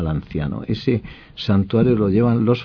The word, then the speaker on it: Lanciano: Se pronuncia Lanciano.